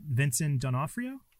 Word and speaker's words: Vincent D'Onofrio: But for all of my back and forth qualms about the awkwardness, I will say it's incredible acting on, and I always get his name pronounced wrong, so I'm going to try it, Vincent D'Onofrio? – I'll take it.